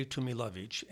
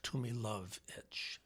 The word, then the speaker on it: Tumilovitz: So people ask me, how do you say Tumilovitz? And I'll say, if it's like too much luggage, you're pretty close. And the accent's on love, Tumilovitz. So Tumilovitz.